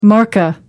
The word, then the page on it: markka: American English